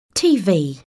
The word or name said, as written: TV